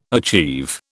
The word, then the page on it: achieve: British English